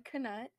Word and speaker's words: Knut: Yeah, so, Kaylin pronounces Knut with the K. So, like I just said, Knut.